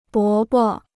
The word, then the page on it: 伯伯: bó bo